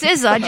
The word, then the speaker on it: SZA: Oh, is that how you say it's SZA? S-C-A, it's yes. – SZA? – Yeah. Yeah, so like rappers from Wu-Tang Clan, RZA and GZA, she got her name from the same similar way.